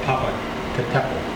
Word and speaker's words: Popocatépetl: It's called Popocatépetl.